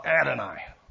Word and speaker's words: Adonai: In the year of King Uzziah's death, I saw Adonai, Lord, general expression Lord, sitting on a throne.